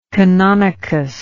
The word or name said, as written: canonicus